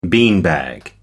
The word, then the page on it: beanbag: American English